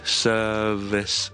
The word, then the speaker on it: service: 但系咁喺呢个字度咧，个 V 咧的确系读 V 音嘅。咁我哋读咧 service service service。